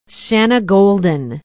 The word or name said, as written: Shanagolden